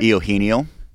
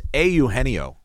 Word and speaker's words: Eugenio: You pronounce his name? – Eugenio. – It's Eugenio. Eugenio.